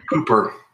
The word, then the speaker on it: Hooper: Just like Hooper. Rhymes with pooper. Hooper. Hooper scooper.